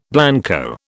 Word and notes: blanco: British English

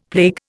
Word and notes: plague: Indian

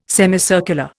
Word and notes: semicircular: Indian

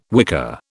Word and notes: wicker: British English

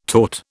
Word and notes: tort: British English

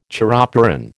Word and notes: chiropteran: American English

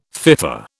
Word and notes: FIFA: British English

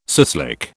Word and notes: suslik: British English

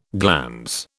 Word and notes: glans: British English

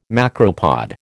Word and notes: macropod: American English

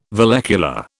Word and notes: vallecula: British English

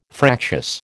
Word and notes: fractious: American English

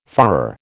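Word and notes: führer: American English

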